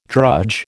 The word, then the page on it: drudge: American English